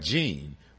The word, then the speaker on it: Jean: It is pronounced, last name Jean? – Yes. – Jean. Mm-hmm. That's French.